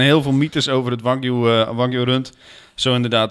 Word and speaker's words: Wagyu: Nee, je hebt nog eh, eh, eh, Wagyu, W-A-G, uh, Griekse I-U. Dat is heel duur, hoor ik. Dat is inderdaad heel duur spreek je uit als wagyu. Want wa betekent Japan en gyu betekent koe.